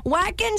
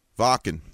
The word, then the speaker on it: Wacken: She's using the English pronunciation. If you were to go with the traditional German where W's take the place of a V, Wacken.